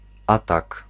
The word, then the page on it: attack: /əˈtæk/